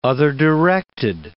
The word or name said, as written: other-directed